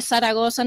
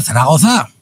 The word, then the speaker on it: Zaragoza: ¿Se dice Zaragoza? – ¡Ah, Zaragoza! – Es la única ciudad que tiene acento en todas las vocales.